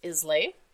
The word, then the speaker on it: Islay: We pronounce it Islay. – Right, Islay is the, and we're going to spell that for you because...